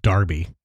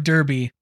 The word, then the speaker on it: Derby: I think it's pronounced Darby because it's No. Oh. This is the Great Stork Derby.